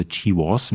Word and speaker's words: GWAS: Ja, das nennt sich auch GWAS oder GWAS wird es ausgesprochen, Genome-Wide Association Studies.